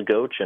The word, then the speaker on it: Ghosh: Most people say Gotch or something odd like that, but nope, it's my whole life I've been told it's pronounced Ghosh, and apparently that's what, you know, great-great-grandpa who came over from Germany used to say, so they pronounce it Ghosh, so we're sticking with it.